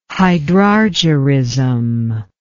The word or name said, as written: hydrargyrism